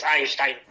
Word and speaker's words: Einstein: Más si lo pronuncias como corresponde, que es Einstein.